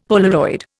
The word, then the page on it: Polaroid: Indian